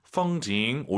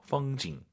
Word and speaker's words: 风景: 펑징, 올라간 순간, 엄밀히 말씀드리면 틀린 발음이에요. 뒤에가 사성이잖아요. 반삼성이죠. 펑징, 끝내야 됩니다. 풍경이 중국어로? – 펑징.